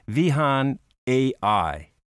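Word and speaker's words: Vihaan.AI: It's all part of their transformational plan called, and I'm not quite sure about the pronunciation, but I believe it's Vihan AI.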